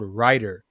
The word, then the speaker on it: Rytr: Another platform that I'm I've been using as well is called Rytr, and it's spelled R Y T R and Rytr is is pretty similar to Chat GPT, what it but it's specifically focused on writing blogs, article, um, yeah.